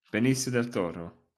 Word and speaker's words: Benicio del Toro: Benicio del Toro.